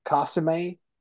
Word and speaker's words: consommé: And then I have to put a can of beef consommé.